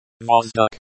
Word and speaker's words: Воздух: Воздух, the V is like the V in very. The O is like the O in hot. The Z is like the Z in zoo.